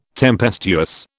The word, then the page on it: tempestuous: British English